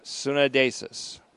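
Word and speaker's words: suneidesis: Conscience, another word, this time Sunedasis or Sunedasis, S U N long E, conscience, Sunadesis, D E S I S. Make that E I. S U N E I D E S I S. EI and long E sometimes sound exactly alike, but it's S U N E I D E S I S. Sunadesis.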